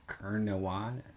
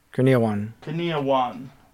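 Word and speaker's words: Kurniawan: How do you say that? – Kerniawan. – Kerniawan. Rudy Kerniawan. Okay.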